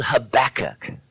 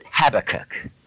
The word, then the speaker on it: Habakkuk: Maybe look it up in your table of contents, but it's a book called Habakkuk, or as some pronounce it, Habakkuk.